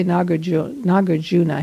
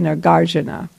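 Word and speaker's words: Nāgārjuna: Then we had break from 4 to 4:30, and at 4:30 until 6, Venerable Children taught again on, now there's two ways to pronounce this. We say Nagarjuna here. They say Nagarjuna.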